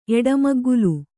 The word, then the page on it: ಎಡಮಗ್ಗುಲು: eḍamaggulu